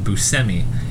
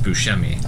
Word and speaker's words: Buscemi: Buscemi grew up pronouncing his name Buscemi in an anglicized way. In Sicily, where his ancestors are from, it is pronounced Buscemi. – Oh, he once remarked, I had to go to Sicily to find out I pronounced my name wrong.